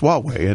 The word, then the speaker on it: Huawei: And it doesn't look like it should be Huawei. If you've ever seen the name, it's H-A-I-W-U-A. – Yeah, it's something weird. But it's pronounced Huawei, and I've learned that in China years ago. Asking their CEO, how do you pronounce the company name? And he told me in Chinese. And I went, no. How do you pronounce it in English? And it was Huawei.